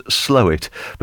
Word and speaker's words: Slaithwaite: And she says, I want to point out, as a proud northerner, that Slaithwaite, you said just a few minutes ago, is pronounced Slowit.